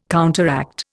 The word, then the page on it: counteract: Indian